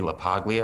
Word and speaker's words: LaPaglia: I went up to Anthony LaPaglia, I think that's how you pronounce his name. – Oh, yes.